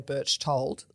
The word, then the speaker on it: Berchtold: Well, um, my father's German, and if I go back to Germany uh, and say Birchtold, like I do here in Australia, they would just look at me blankly.